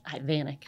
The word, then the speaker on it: Ivanek: We say it like Americans, just Ivanek.